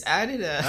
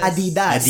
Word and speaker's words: Adidas: I know it's 3 syllables. Adidas store? – Okay. – La. – Adidas. – Adidas. – Adidas. – It's Adidas. – Oh. – Because we Filipinos would usually say Adidas.